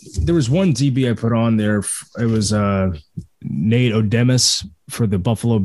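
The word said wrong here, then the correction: Odomes: I think it was Nate Odoms, wasn't it? – Odoms.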